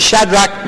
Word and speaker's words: Shadrach: นะคะส่วนอาจารย์วอนนะคะตอนที่ท่านพูดถึงดาเนียลชัดรักมีช้างอเปตเนโกนะคะอาจารย์วอนนี่ท่านเป็นศิษยาภิบาลผิวดำนะคะ The correct pronunciation of those words are Shadrach, Meshach, and Abednego.